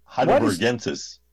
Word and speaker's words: Heidelbergensis: Why does Homo heidelbergensis, or gensis, or gensis? How do you pronounce it? – Hard G. – Okay. – Heidelbergensis.